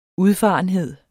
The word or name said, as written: udfarenhed